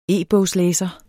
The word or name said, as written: e-bogslæser